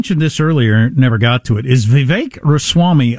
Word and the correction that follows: Ramaswamy: a fajita or a taco? Ramaswamy. I left out a syllable.